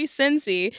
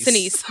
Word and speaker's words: Sinise: Sinise, excuse me. There is a pronunciation here. I'm glad you're here, because even with the pronunciation, I was still going to get it wrong.